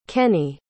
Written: kenney

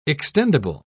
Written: extendable

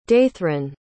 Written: datherine

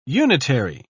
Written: unitary

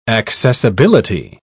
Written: accessibility

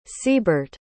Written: seaburt

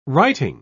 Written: writing

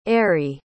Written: Ary

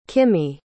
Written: kimmi

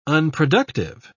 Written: unproductive

